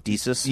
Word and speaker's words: Desus: Would I say it wrong? Desus? – Yeah, I think it's Desus and Marrow, though I might be mispronouncing it too. But... – No, you're saying it right. I feel like it's so close to Jesus that I have Desus, that I always get that part wrong.